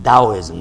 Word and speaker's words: Taoism: Every other religion tells you, if you become Hindu, if you become Buddhist, if you become Taoist, you know, Taoism, I think it's Taoism, they pronounce it like a D, right?